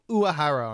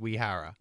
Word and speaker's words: Uehara: And you'd think they'd force Uehara, who they keep calling Uehara on TV. Mm-hmm. I thought it'd be like Wahara or Wehara. – Mm-hmm. – I'm pretty sure that he pronounces it Wehara.